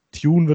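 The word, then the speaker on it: Thioune: Tune wird er ausgesprochen, Tione geschrieben, das verwirrt mich bis heute.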